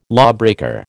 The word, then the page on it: lawbreaker: American English